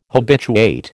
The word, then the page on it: habituate: American English